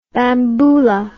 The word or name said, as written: bamboula